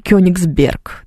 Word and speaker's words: Кёнигсберг: Еще какое-нибудь слово, например, «Кёнигсберг», да, вот тоже ударение на «ё», а вот на «е» на последнее, а на «ё» такое будет полуударение, так вот скажем.